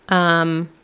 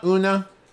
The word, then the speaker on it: Oonagh: And they are Eileen, Ona. I'm not quite sure how to pronounce that. – How's it spelled? – O-O-N-A-G-H. – Yeah, Ona, Una, something like that.